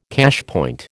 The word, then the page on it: cashpoint: American English